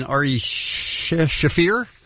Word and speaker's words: Shaffir: Comedian Ari Shaffir. Why do I always try to say Schaefer? – Sure. – I have a good friend named Schaefer, that's why.